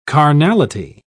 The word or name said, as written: carnality